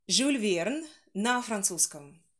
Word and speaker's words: Jules: В оригинале он произносится именно Жюль, Жюль, а в английском все-таки вот первое, как вы назвали, Jules, совершенно верно, через «дж» звук, вот эта буква сочетания July, например, June из той же серии Jules, Jules, yeah, Jules Verne.